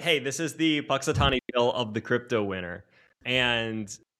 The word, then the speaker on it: Punxsutawney Phil: Am I saying that right? The Punxsutawney Phil? – Yeah, Punxsutawney Phil.